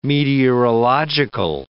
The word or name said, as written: meteorological